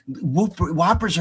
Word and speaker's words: Whopper: Like, Dad, Whoppers are not at McDonald's, and it's pronounced Whopper. It's not Whooper, whatever.